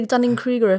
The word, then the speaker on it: Dunning-Kruger: Well, it's all very Dunning-Kruger curve. Is that how you say it? I should definitely know how to say that rather than just Done the Dunning-Kruger effect.